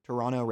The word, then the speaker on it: Toronto: They don't pronounce the T, I learned. Toronto Raptors.